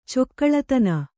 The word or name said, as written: ಚೊಕ್ಕಳತನ